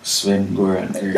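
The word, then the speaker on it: Sven-Göran Eriksson: Yeah, Sven Goran Eriksson.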